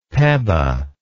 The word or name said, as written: peba